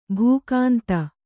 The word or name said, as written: ಭೂಕಾಂತ